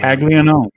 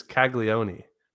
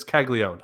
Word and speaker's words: Caglianone: That's not how they pronounce it, though. That's not, it's Caglione. Caglione. It's Caglione. I said it right. – Caglione. Yep. – God, that's so bad. – That's right. The A is kind of like super soft, almost silent.